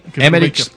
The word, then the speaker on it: Emmerich: Sí, Emmerich se deletrea E-M-M-E-R-I-C-H. Rich. – Pero las últimas sílabas son rich. – Como de rico.